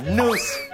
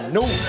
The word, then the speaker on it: nurse: Cái y tá đó ta đọc là nurse chứ không phải là nu.